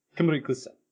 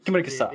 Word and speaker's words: Kemurikusa: I just go from the video, the intro video, where he says it really fast. Kemurikusa. Kemurikusa.